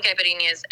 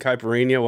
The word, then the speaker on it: Caipirinha: Caipirinha. – So the NHA is kind of, or NHO represents kind of like how we birthday, something cute like puppy, kitty.